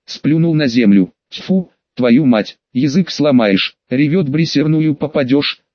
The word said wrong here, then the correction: реверсивную: «Полудурок ты, Колька. Правильно говорить в реверсивную, Поправил его Петрович и выпрыгнул из кузова.